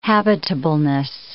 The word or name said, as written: habitableness